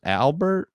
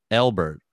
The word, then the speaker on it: Albert: I feel like people are going to spell my name E-L-B-E-R-T because you always say it like that. – Oh, sorry. – I need to get this off my chest, bro. You don't know how say my name. No, it's fine. It's just, how do you say your name? – It's just Albert.